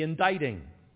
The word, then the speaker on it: indicting: And here's one of our English words that doesn't sound like it's spelled. The indicting. The indicting. And that's spelled I-N-D-I-C-T. Looks like indicting. I-N-D-I-C-T-I-N-G.